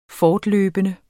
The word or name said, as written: fortløbende